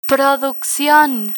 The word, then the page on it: produccion: Spanish